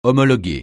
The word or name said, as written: homologuer